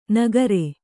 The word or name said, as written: ನಕ್ಕರಿ